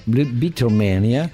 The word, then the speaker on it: Beatlemania: in Inghilterra Beatlemania, come tu sai la pronuncia è diversa. – Come pronuncia bene, prof? – Beatlemania.